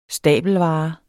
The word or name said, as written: stabelvare